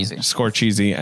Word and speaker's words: Scorsese: And then you got Greta Gerwig, the Safdie Brothers, Martin, Scorsese, who's up and coming. – Yeah, I think it's pronounced and Celine. Scorcheesy and Celine.